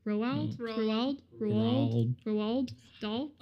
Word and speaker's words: Roald Dahl: Other in new news, Taika Waititi is reimagining Ronald Dahl's Worlds for Netflix. So if you don't know, or I pronounced that wrong. Roald Dahl.